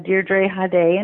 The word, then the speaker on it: Deirdre Hade: Well, my website is my name, which is Deirdre Hade, and it's spelled Deirdre, and then Hade.